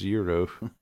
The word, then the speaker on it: gyro: I meant my gyro. I'm just kidding. – It's pronounced Euro.